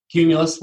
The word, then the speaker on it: Cumulus: Along with this, is a director of Cumulus. Is that how you say it? – Cumulus, like the cloud.